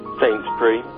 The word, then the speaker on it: Saintsbury: Is that how you pronounce it over there, Saintsbury? – Saintsbury, yes.